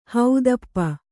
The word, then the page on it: ಹೊದಪು: hodapu